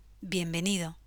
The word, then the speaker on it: bienvenido: Say hola, bienvenido. The first syllable, bien, is similar to vie in Vienna. The last syllable, do, sounds similar to the word do, being the O sound shorter.